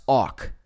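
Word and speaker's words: awk (אח): Okay, so this was Strong's Concordance number 251, and it's pronounced awk. Phonetically, they spelled it A-W-K-H, awk.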